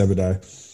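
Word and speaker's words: Obadiah: And if you haven't read this story, is it Abadiah? Is that how you say it? – That is right. I don't know the story you're going into, but yeah, it's Abadiah.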